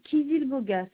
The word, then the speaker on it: Kizilboga: Alors, c'est Madame Kizilboga. Ça s'écrit K-I-Z. – Oui. – I-L. – Oui. – B comme Béatrice, O-G-A.